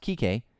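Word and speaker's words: Kiké: I talked about Kike because it's pronounced Kike. But the spelling of his name can be misconstrued if you're not saying it with the accent.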